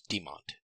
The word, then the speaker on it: Dhimant: But when people don't have a chance to hear you say it in the simplified way, so I use Dhimant as a simplified way of saying my name.